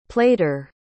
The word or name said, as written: plaiter